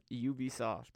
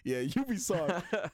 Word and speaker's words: Ubisoft: The official pronunciation of it is Ubisoft. – Yeah, Ubisoft.